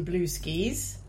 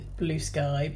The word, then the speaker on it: Bluesky: It cannot be pronounced Blueski, can it? – I don't pronounce it Blueski. I'm going to pronounce it Blue Sky.